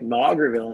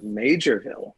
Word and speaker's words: Maugerville: And then you made a massive mistake and mispronounced a town, calling it Maugerville instead of Majorville.